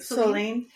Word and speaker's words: Soline: Did I say that right? – Soline. – And then is it Soline? – Soline. Mm-hmm. It's a French name.